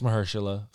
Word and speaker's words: Mahershala: I hope I said his name right, Mahershala. – You got it. – I couldn't remember if it was Mahershala or Mahershala, but it's Mahershala from what I've heard.